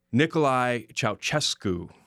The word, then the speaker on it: Nicolae Ceaușescu: I'm going to try real hard here. Nicolae Ceaușescu. Mm-hmm. Is that correct? – We'll have to ask Ben in a few moments. But yes, I would use that pronunciation.